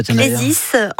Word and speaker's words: Clésis: Ça se prononce comme ça, oui. – Pourquoi d'ailleurs ça s'appelle comme ça? – Clésis, en grec, ça veut dire vocation.